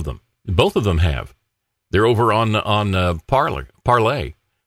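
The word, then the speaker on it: Parler: Just got on the Parlay. I keep saying Parlor because that's the way it's spelled.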